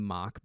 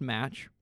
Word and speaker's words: Mach: So that I can do the mock bike. – Okay. – Not pronounced match, by the way. I used to pronounce it that way. M-A-C-H.